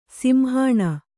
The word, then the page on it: ಸಿಂಹಾಣ: simhāṇa